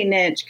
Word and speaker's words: niche: I think that's what a niche is all about, or a niche, or I say niche because it rhymes with rich, but you know, I think that's what a niche is all about.